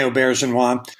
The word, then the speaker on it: Auberjonois: Renee Auberjonois. Auberjonois. – Rene Auberjonois.